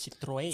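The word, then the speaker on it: Citroën: kui me hääldusest räägime, siis Citroen.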